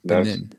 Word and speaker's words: Benin: Yeah, Benin.